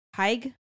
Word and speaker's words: Haig: Is it H-A-A-G? – H-A-I-G. Close. – Haig. – Haig. – Mm. We could ask the Frenchman. It is pronounced Haig.